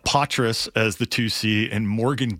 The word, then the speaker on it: Poitras: Poitras. That's how they pronounce it.